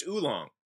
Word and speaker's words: oolong: So Young Lung is it's pronounced oolong.